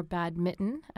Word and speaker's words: badminton: We always said badminton. We never pronounced the N for reasons I'll never understand.